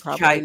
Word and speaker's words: Cheit: But thank God for Ross Chate. I hope I'm pronouncing his name right. – Oh, it's Chite, not. – By the way. I only know that thank because you. In interview with Dr. Loftus, I was saying Chate, and she's like Chite, which is one of the second most embarrassing interview moments in my life.